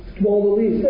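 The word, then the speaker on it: Camaldolese: On Tuesday nights in Berkeley, the Kamalanese, I never can pronounce that word, but they're Benedictine monks. Kamalanese, thank you.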